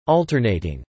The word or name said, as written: alternating